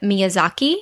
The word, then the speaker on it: Miyazaki: Next up, Lauren is a huge fan, calls him a hero of, and correct me on my pronunciation here, of Miyazaki. – I think that's right.